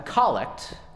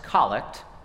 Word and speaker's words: collect: A collect, which is pronounced collect, not collect, is the opening prayer that changes each week, that gathers us together and focuses our prayerful intentions for the week.